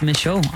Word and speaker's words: Micheaux: Oscar Michaud. M-I-C-H-E-A-U-X. Michaud. Oscar Michaud.